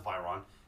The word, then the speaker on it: Veyron: The new Bugatti Veyrons drive, like, drive, like, a few million. – Also, no, it's not a few million. God, first of all, it's pronounced Byron.